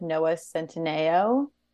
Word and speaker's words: Centineo: She was very briefly rumored to be with Noah Centineo.